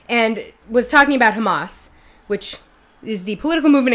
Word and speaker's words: Hamas: In any case, he mispronounced it in several different ways, several different times, but the most notable was that he, at some point, called it hummus.